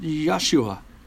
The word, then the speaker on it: Yeshua: In Hebrew, it's pronounced Yeshua, or Joshua, we might recognize it. In the Greek, it's Iesus. But what's significant are the supernatural signs surrounding this Jesus in particular.